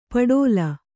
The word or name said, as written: ಪಟೋಲ